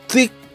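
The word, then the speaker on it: تكة: البته من از ایلیا جزائری رفیق و همکارم که عربی بلده پرسیدم تلفظ درست این غذا چیه، گفت تیک.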